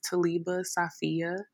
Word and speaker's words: Talibah Safiya: Where you can find me, my name is Talibah Safiya, and that's spelled T-A-L-I-B-A-H-S-A-F. I-Y-A,